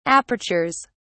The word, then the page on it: apertures: British English